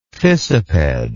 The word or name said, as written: fissiped